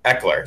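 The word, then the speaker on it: Ekeler: and I think Austin Eckler.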